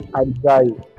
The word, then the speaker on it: Isai: Hello guys, this is Isai. Okay. Mimi napenda pronounce it as Isai. Hata my Instagram username ni Isai. Isai.